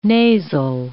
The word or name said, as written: nasal